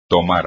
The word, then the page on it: tomar: espagnol